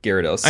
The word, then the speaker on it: Gyarados: Guy Fieri, Gyarados. – I only fuck with 3 stars. – But it's spelled G-Y-A, you know, Guy Fieri.